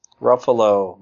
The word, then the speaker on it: Ruffalo: And I love the way that, I'm going to mispronounce his name, Ruffio. – Ruffalo. – Ruffalo. There's an L in there. I always remember that. It's Buffalo with an R.